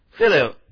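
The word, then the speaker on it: phileo: Filio, P-H-I-L-E, long O, filio.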